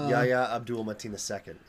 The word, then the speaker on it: Yahya Abdul-Mateen II: Yahya Abdul-Mateen II is his name. So I think I mispronounced it the first time, but not that second time that I just said it.